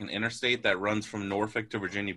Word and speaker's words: Norfolk: Yeah, Norfolk.